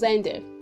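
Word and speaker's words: Xender: So, the application is pronounced as zender. So you say zender and not exton.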